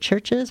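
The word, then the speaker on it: Chvrches: The band spells their name using the Roman letter V instead of U in the word churches, but it's still pronounced churches. And the spelling was chosen to differentiate themselves from actual churches in online search results.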